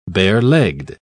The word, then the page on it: barelegged: British English